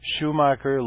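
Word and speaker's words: Shoemaker: and look up pictures of comet Schumacher Levy 9. And so that's spelled Shu, just like Shu, S-H-O-E. Macker, M-A-C-K-E-R, that's Sir Gene Schumacher.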